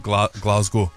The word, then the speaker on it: Glasgow: Can you pronounce the name correctly, please? – Glasgow. – Glasgow. I don't know why, but we've all of a sudden adopted the Glaswegian pronunciation of that city.